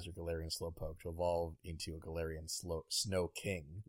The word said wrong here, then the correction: Slowking: Nice. – Slow King. Yeah, right. I said that right.